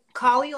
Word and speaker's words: Kali: Kali like cauliflower.